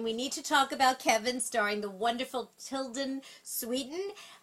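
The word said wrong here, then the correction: Tilda Swinton: Hopefully I pronounced her name wrong. – Tilda Swinton, and Martin Scorsese's Hugo.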